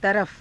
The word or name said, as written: tarafa